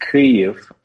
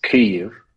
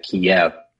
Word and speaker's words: Kyiv: Yeah, there are slight differences in the languages, and so in Ukrainian it's pronounced Kyiv, and since Ukraine is making a big point about using Ukrainian language internationally, the transliteration is Kyiv with the K-Y-I-V. But you know, before Russian, all the names of places and things were in Russian, and so everybody's gotten used to Kyiv, or as Americans say it, Kiev.